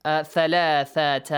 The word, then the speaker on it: ثلاثة: قال آيتك ألا تكل أن لا it is but merged إدغام ألا تكلم الناس ثلاثة أيام إلا رمزا ثلاثة pay attention to the pronunciation of the letter ثا ذال عين همزة yes حا these ones ثلاثة